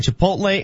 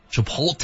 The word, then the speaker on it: Chipotle: Uh, this guy says, damn it, the L is not silent in Chipotle and it's not hard.